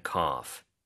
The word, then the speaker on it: cough: I have a pretty bad cough. – I have a pretty bad cough. C-O-U-G-H. – 네, C-O-U-G-H이고요. G-H가 이렇게 끝에 올 때는 보통 F로 발음을 하죠. 그래서 cough이고요.